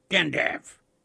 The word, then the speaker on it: Gandalf: How do you pronounce G-A-N-D-A-L-F? Gandalf. Gandav? No. It rhymes with half, like half of sixpence. Gandalf.